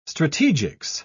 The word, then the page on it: strategics: British English